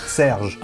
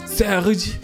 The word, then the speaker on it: Serge: Serge. – Oh. – Pardon. – Claro, me faltó decir que es francés. Serge.